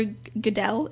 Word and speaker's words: Goodell: NFL Commissioner Roger Goodell, is that how you pronounce Goodell. – It? Goodell. He made a statement saying that his,